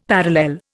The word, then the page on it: parallel: Indian